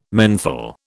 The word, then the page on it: menthol: British English